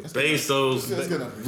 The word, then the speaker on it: Bezos: Bezos, richest man in the fuck world.